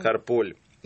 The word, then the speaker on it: Карполь: Карполь, да, ударение? Или Карполь, как? Карполь. Карполь.